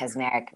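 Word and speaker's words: Kacsmaryk: And is that how you pronounce his name? – Kaczmarek? Yes, Kaczmarek.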